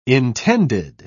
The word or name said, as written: intended